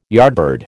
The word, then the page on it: yardbird: American English